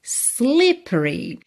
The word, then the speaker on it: slippery: Slippery. Esse E do meio não é pronunciado.